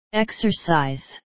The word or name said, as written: exercise